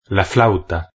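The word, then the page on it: la flauta: Spanish (Spain)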